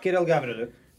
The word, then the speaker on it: Kirill Gavrylyuk: Kirill Gavrylyuk.